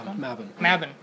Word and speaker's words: Mabon: Now, however, it seems that there was only 1 year gap between Lotusville and your most recent, Mabon. Is that how it's pronounced, right? – Mabon, my bad. Okay, Mabon. So Mabon.